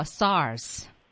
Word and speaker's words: SARS: 好，我们来学重温一下啊，这个 SARS 的英文拼写 SARS，英文中会读作 SARS。